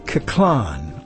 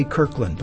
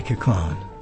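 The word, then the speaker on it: Koechlin: That was composed by Charles Kaclan, I believe it's pronounced. I want to say Kirkland, but it's not. It's Kaclan, I think.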